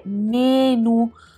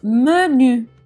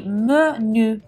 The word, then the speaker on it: menu: In francese non si dice menu con la E aperta e la U, ma menu, quindi con una E di fatto chiusa, quasi muta, e la U che è una via di mezzo tra una I e una U, quindi menu.